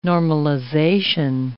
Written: normalization

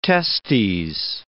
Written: testes

